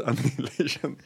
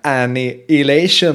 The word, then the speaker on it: Annihilation: Som vi också har fått skit för hur vi uttalar. – Ja, jag vet. Annihilation. Så säger man ju inte.